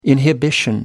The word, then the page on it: inhibition: American English